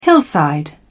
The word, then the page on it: hillside: British English